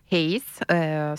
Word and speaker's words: Hayes: Hayes, sì, dovrebbe essere questa la pronuncia corretta,